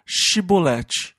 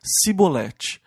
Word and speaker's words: Shibolete: E eles falavam: "Diga Shibolete." E os homens de Efraim não conseguiam pronunciar o CH e diziam Sibolete.